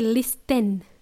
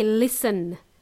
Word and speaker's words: listen: Okay, the word listen, first of all, the letter T is silent. We don't say listen, we say listen.